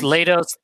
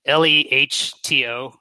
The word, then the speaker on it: Lehto: Yeah, Chris Lehto, Lehto. It's Finnish. It's spelled the wrong way. I was considering changing it. The H comes before the T.